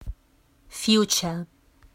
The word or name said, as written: future